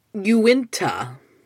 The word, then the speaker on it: Uinta: I think I mispronounced the location, the area that the ranch is in. I'm going to try it one more time. Uinta, according to my proper search.